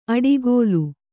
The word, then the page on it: ಅಡಿಕೋಲು: aḍikōlu